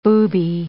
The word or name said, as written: booby